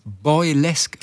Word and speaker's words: Boylesque: Bo äh, das Boy, Boy, wie Boy und Girl. – Ja. – Und dann Lesk mit QUE hinten. – Ah, Boylesque. wie Burlesque. Okay. – Burlesque, nur okay. eben Boylesque.